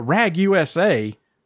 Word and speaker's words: Ragusa: And it's spelled RAGUSA. Immediately I thought, RAGUSA. No, it's Ragusa. – Ragusa. – It's a place in Italy.